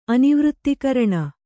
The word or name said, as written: ಅನಿವೃತ್ತಿಕರಣ